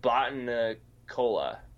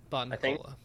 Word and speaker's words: Botanicula: Botan Cola Botanicula? Botanicula.